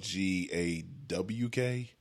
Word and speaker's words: Gawk: I think it's spelled G A W K. – Nah, Gawk. – Gawk. Yeah, Gawk 3000.